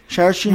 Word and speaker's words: Saoirse: Saoirse Ronan, som er 13 år i filmen, i hvert fald, som er Oscar nomineret for rollen.